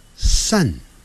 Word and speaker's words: sun: 太阳叫 sun，哎，不要念成 sun，不是念 sun 啊。你看，有点像一二三的三，sun。